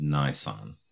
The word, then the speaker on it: Nisan: Today, it is known by the Jews as the month of Nisan, N-I-S-A-N, Nisan. The name change took place during the Babylonian captivity, and it's pronounced Nisanu. But the Jews call it Nisan.